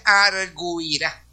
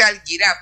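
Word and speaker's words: argüirá: Si usted analiza y mira ahí, la palabra dice arguirá. Uno puede leer quizá que dice alguirá, pero alguirá se puede decir cuando no hay una diéresis o crema en la U. Porque la U tiene una pronunciación ahí, que se llama esa acentuación dentro de la regla de acentuación del vernáculo, del español. Al ponerle esa diéresis o crema, dice arguirá. La U tiene que pronunciarse.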